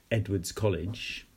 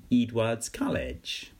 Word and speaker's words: Edwards College: And he was there as a volunteer, I think, for 2 years, teaching at a place called, as we would pronounce it, Edwards College, but as was pronounced locally, very interestingly, Edwards College. So we took a while to get used to the different ways of saying it.